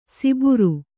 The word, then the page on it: ಸಿಬುರು: siburu